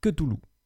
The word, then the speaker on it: Cthulhu: Cthulhu se prononce Cthulhu. Voilà, désolé, mais ça fait vraiment mal aux oreilles.